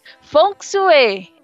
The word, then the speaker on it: Feng Shui: Feng Shui, é assim a pronúncia.